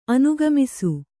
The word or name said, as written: ಅನುಗಮಿಸು